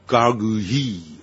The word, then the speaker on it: gargouille: The word gargoyle comes from the French word gargouille， gargouille 这样的发音，我的法文并不行，但是大概有个样子啊。那么这是来自于法国法文，这个字叫 gargouille， gargouille， which means throat，它这个原来的意思是指的是喉咙的意思。